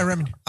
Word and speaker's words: Remini: Leah Remini? I think I said that. Oh, did I say that right? Okay, cool. – Yeah, Remini.